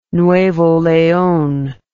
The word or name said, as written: Nuevo Leon